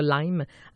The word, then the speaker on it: Lyme: Alors voilà donc Dr. Perron qui est un expert de la maladie de Lyme ou de Lyme. Il y a deux façons de le prononcer.